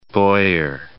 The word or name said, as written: Boyer